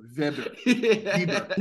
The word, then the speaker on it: Weber: So first thing I'm going to do when I get there is I'm going to go to a barbecue store and see if they pronounce Weber Vibber. – No, that's I'm saying that Vancouver thing. No, it's a German pronunciation. You don't pronounce a W.